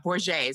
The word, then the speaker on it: Borget: Oh, gosh, it's not. Borget is how you pronounce my last name.